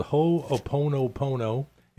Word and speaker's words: Ho'oponopono: It's called Ho'oponopono, Ho'oponopono.